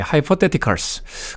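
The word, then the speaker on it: Hypotheticals: 레이크 스트리트 다이브의 하이퍼테티컬, 예, 테티컬스, 네, 하이퍼테티컬스, 가설들이라는 뜻을 가지고 있다고 합니다. 발음기호까지 찾아봐야 될 정도로 낯선 단어였는데, 노래 굉장히 좋네요. 레이크 스트리트 다이브의 하이퍼테티컬. 태가 아니고 때입니다. 때. 영어 발음 뜨 발음이 나는 네.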